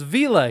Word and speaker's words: Villa: I said Villa. It's Villa. He just said Villa, like Bob oh, Villa.